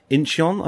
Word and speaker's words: Incheon: He said he's been listening from Incheon, I think, today.